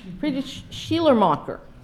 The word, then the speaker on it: Friedrich Schleiermacher: Friedrich, spelled with R-I-C-H, though. Okay. French, German. Spanish, I can do. German, not so much. Friedrich Schleiermacher.